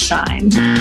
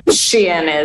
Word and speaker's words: Shein: 这因此就衍生出一种非常另类的 Shein 开箱影片。 – Hey guys, if you've ever been anywhere near TikTok lately, you've probably seen a lot of this. – Y'all, the Shein package that I was waiting for arrived, so let's open it. And it's really heavy, so... – Welcome to the world of shine. Yes, like I don't think it's shine. Shein is how I tend to pronounce it.